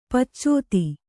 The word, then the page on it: ಪಚ್ಚೋತಿ: paccōti